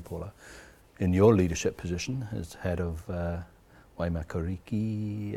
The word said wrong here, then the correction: Waimakariri: have I said it right? – Waimakariri. – Oh, so close.